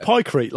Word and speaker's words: pykrete: it's pronounced pycrete, like concrete, presumably.